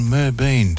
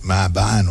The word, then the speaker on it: Merbein: Bakers and vanilla slice enthusiasts from near and far will converge on Merbein today, even though they pronounced it wrongly on the news last night, Marbein or something they called it, today to find out who has baked the ultimate award-winning vanilla slice.